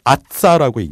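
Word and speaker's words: AASSA: 아츠, 아싸라고 읽는데요. – 어, AASSA라는 철자로 되어 있습니다. 이거를 네. 그냥 우리말로 읽게 되면 아싸인데요.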